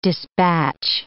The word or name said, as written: despatch